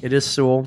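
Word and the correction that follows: Soule: Okay. – No, Soul. Charles Soul. I'm sorry. Like okay. The sole of your shoe.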